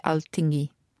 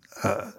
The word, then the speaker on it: Alþingi: How do you pronounce it? Do you know? – Alþing. – I should know this. It's Alþing. Yeah. – Alþing.